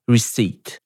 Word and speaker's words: receipt: I tu jest tak samo jak w doubt, to znaczy pojawia się litera w pisowni, której nie ma w wymowie. A więc czytamy receipt.